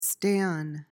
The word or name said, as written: stan